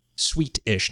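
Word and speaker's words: sweetish: Next door, where residents became sick and complained of a Swedish gross smell. Sweet-ish, not Swedish.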